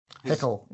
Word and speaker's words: Haeckel: All right, then let's go to, is it pronounced Haeckel? – Haeckel, yeah.